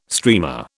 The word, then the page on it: streamer: British English